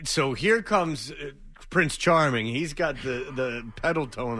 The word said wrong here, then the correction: Peloton: or whatever. Pedalton. – Peloton.